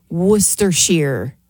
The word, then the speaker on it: Worcestershire: Worcester sauce. That's how I say it. It's Worcestershire.